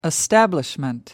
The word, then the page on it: establishment: American English